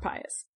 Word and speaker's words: Pius: Some people say Antoninus Pius, but it's spelled the exact same way as Pius, so we're going with Pius today. Saint Justin the Martyr is writing directly to the emperor and directly to the Roman Senate.